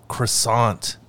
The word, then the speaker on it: croissant: T at the end of croissant is definitely silent, so do not say croissant.